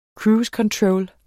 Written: controller

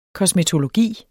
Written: KOSMETOLOGI

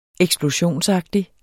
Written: eksplosionsagtig

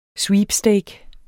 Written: sweepstake

